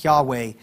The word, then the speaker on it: Yahweh: it's referring to YHWH, or we often pronounce it Yahweh.